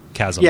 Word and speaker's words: chasm: I have to take issue with the way that you pronounce that. I don't normally do that. It's Chasm of the Abyss. Chasm.